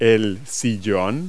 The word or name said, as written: armchair